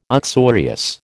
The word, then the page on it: uxorious: American English